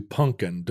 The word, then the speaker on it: pumpkin: So my mother, who grew up in Ohio on a farm, refuses to acknowledge that there is a second P in the word pumpkin. – Or indeed an M. – Right. Pumpkin pie is the way she insists on it being pronounced.